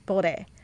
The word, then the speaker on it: Bode: Her voice is amazing, and Hanani Bode? Did I say that right? – Bode.